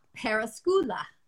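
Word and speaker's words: peruskoulu: Perkoloso, whatever that word is. – Oh, I looked up how to say it. Let me tell you, I did the like Google Translate. It's Peruskoula.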